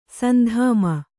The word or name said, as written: ಸಂಧಾಮ